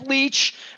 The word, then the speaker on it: Leitch: He's Will Leach. He is here. You can follow him at William F. Leach. It's spelled a little funny, L-E-I-T-C-H, if you're looking for him.